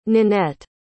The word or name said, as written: ninette